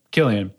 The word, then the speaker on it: Cillian: And yeah, this movie just like Cillian Murphy, is that how you say his name? – Killian. – Yeah. Killian Murphy is just such a great leading man.